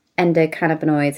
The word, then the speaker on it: endocannabinoids: The phrase that, you just get loads of endorphins when you exercise, but actually it's the endocannabinoids. Have I said that correctly? – That's correct, yeah.